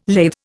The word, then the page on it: lathe: Indian